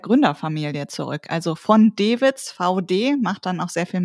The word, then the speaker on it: Vaude: Ich muss ja zugeben, ich dachte immer, das spricht man anders aus, nämlich Wot. – Wot klingt irgendwie französisch, aber ich habe es auch erst verstanden, als es mir jemand gesagt hat, wie es wirklich ausgesprochen wird.